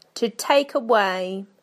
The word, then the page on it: to take away: British English